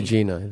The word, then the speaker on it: Rusesabagina: which documented the hotel manager Paul Rusesabagina, or Bagina, I don't remember exactly. Is it a soft G or a hard G? – Bagina.